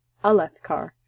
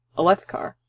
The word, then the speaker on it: Alethkar: Alethkar. Alethkar, yeah, which is not how I've been pronouncing it. I've been saying Alethkar, with Leth, but being emphasized, but I think it's the and the car.